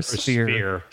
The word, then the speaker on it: sphere: So why do you think more agents don't do more marketing, like, so to speak, or work their spear? Is it just because they don't know? – Wait, are you saying spear or sphere? – Sphere. – Okay. It's a silent H.